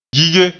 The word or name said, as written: gige